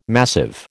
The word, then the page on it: massive: American English